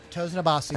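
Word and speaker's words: Tosin Abasi: I got Toes in a Bossy from, and I'm probably saying that wrong. – No, that's it. Toes in a Bossy.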